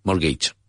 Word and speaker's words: mortgage: Mortgage. – Mortgage. – Strange word. The T is silent and it's more like mortgage.